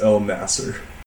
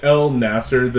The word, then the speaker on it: Elnasser: Elnasser. The N goes after the L. Everything else is perfect, and I get that name butchered all the time, so I'm completely fine with it.